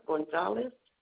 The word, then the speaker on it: Gonzalez: Belinda Gonzalez. – Yes, ma'am. Can you spell that last name for me? – G-O-N-Z-A-L-E-Z.